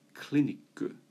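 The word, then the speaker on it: kliniku (クリニック): But in Japanese, that would often be written or printed on a sign or pronounced in conversation as katakana English kliniku.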